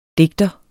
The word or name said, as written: DIGTERNES